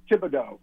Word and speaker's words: Thibodeau: Thibodeau.